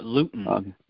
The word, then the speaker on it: lutein: Also, lutein is good for the eyes, too. – Lutein, L-U-T-I-N? – Yeah, like gluten, like rutin, but lutein. – Okay, yeah, because this is R-U-T-I-N, rutin.